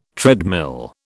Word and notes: treadmill: British English

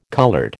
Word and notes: coloured: American English

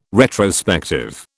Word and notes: retrospective: British English

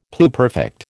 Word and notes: pluperfect: American English

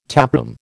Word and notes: taproom: American English

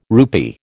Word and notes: rupee: American English

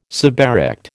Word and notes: suberect: American English

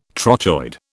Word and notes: trochoid: British English

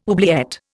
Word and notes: oubliette: Indian

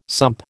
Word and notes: sump: American English

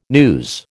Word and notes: news: American English

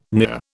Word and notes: near: British English

